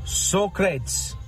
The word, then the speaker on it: Socrates: It is pronounced Socrates. Thank you.